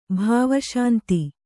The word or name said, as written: ಭಾವಶಾಂತಿ